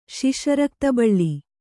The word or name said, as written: ಶಿಶರಕ್ತಬಳ್ಳಿ